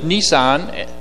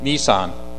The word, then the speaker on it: Nisan: On the 14th day of the Lord, at the first month, which is Nisan, which I'm most likely pronouncing it correctly, N-I-S-A-N, at twilight is the Lord's Passover. That's the Passover feast, on the 14th of Nisan.